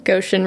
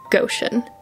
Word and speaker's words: Goshen: Goshen. Not Goshen. Go like go, not gosh golly. Goshen rhymes with motion, notion, ocean, lotion, potion. It's pronounced Goshen.